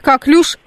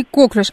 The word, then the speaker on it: коклюш: Мне пишет инвестор, правильно говорить коклюш, а не коклюш. А вы как скажете, как правильно? Коклюш или коклюш? Как вы говорите? – Я говорю коклюш.